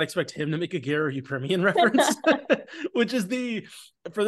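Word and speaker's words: Garo Yepremian: Get his name right. You did make this reference, and I would appreciate Garo Yeprimin. – Garo Yeprimin.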